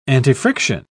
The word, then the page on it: antifriction: British English